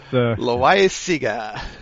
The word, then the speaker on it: Loaiza: I don't know how to pronounce it. What's Loaiza? – Oh, that's right.